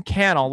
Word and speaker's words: Cannell: Cannell, it's like YouTube channel.